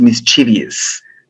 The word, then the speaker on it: mischievous: But it's also mischievous or mischievous. I'm sure how to pronounce that correctly. – I like both pronunciations.